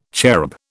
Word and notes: cherub: British English

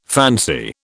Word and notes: fancy: British English

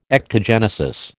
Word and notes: ectogenesis: American English

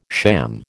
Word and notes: sham: American English

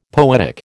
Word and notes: poetic: American English

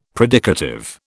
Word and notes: predicative: British English

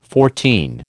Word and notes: fourteen: American English

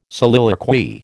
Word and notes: soliloquy: American English

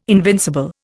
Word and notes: invincible: Indian

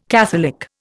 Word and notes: catholic: Indian

